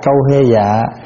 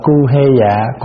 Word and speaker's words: câu hê dạ: Câu hê dạ, câu hê dạ, thể ở đây á có chỗ đọc là cu hê dạ, cu hê dạ.